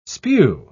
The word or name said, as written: spew